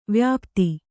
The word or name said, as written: ವ್ಯಾಪ್ತ